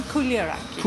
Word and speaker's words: Chouliaraki: Lilie Chouliaraki? – Chouliaraki. – Chouliaraki. My apologies. Thank you for the correction.